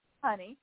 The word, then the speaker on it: Hynni: Let's talk about that, because we talked a little bit about, and it's spelled H-Y-N-N-I. – Right. – And it's pronounced honey.